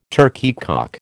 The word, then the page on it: turkeycock: American English